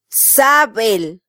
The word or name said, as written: sabel